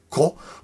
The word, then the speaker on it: Koch: Με, ένα, με H, Koch, δεν πρέπει να προφέρετε πολύ το H εκεί. Blake Κο,